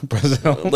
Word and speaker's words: Brazel: Brazel. – Like Brazil, but with L. Brazel. – Brazel.